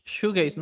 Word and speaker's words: shoegaze: Es como tipo showgaze, showgaze, ¿cómo se pronuncia? Sí. Shoegaze, ¿no? Shoegaze. Exacto. Y mm, está monona, está divertida.